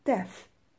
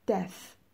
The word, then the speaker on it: Death: Marriage and Death تولد، ازدواج و مرگ توجه کنید تلفظش رو Death Death صداش ف مثل فرفره نیست صداش ت مثل ترقه نیست صداش س مثل صبح نیست صداش ف هستش A birth Diana had a baby boy yesterday.